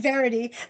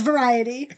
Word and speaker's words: variety: You said Verity. That's one of the words he always mispronounces. It's variety, but he said Verity.